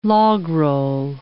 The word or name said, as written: logroll